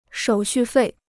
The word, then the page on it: 手续费: shǒu xù fèi